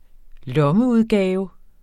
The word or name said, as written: lommeudgave